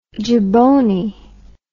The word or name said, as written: jaboney